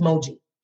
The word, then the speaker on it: Moji: My name is Moji. Okay, cool. We've done that. And then it becomes, oh, Mojo. And it's like, it's four letters, two syllables. Mo, G, right? There's that.